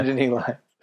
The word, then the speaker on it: Nittany: I think you put an extra syllable in there. Yeah. Nittany Lions.